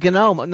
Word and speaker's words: GNOME: Folks at the GNOME Foundation pronounce the G for one reason or another, probably to differentiate it from the word gnome that it's spelled like.